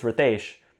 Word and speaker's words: Ritesh: I say, "No, it's Ritesh." Or no, then they say, um, I want to learn the right way. Right. And then I say, oh, it's pronounced Ritesh.